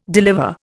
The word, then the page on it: deliver: Indian